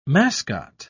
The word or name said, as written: mascot